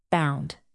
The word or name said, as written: bound